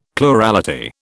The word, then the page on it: plurality: British English